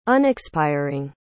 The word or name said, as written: unexpiring